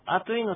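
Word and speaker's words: アトゥイ: で、普通この、あといのトゥーっていうのはですね、トに丸で、をつけて書く、アイヌ語の特殊な発音です。トにちっちゃいウも、ウでもいいんですけどね。あとい。あといじゃな、あといでもないし、あついでもない。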